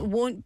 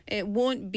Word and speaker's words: won't: 근데 말하는 게 발음 좀 어려워요, 그거. – It won't. – It won't. – Won't. – Won't. 왜냐하면요. Won't. It won't. – It won't. – 노래에 W 소리가 중요한 소리예요. – 아, Because you have will and won't.